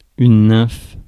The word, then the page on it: nymphe: /nɛ̃f/